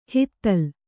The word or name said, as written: ಹುದಲು